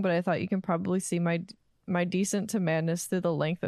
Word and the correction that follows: descent: You pronounce descent in an odd way. – Oh, my descent. My descent. – Yes. – Oh, I read it as decent.